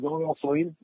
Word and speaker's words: Ali Al Salem: And, was that out there at Ali Al Salem? If I'm saying it correctly. – Yep. Yeah, it was Ali Al Salem Air Base.